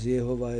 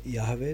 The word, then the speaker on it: Jahve: A tak som sa spýtal, no dobre, takže výslovnosť Jehova je zlá? Áno. Samozrejme, že zlá. A teda Jahve je dobre?